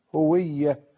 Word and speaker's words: هوية: أولا، كلمة الهوية كلمة مركبة من ضمير الغائب هو، مضاف إليه ياء النسبة للدلالة على ماذا؟ الهوية، هو هوية، وهذا هو الصواب في نطقها.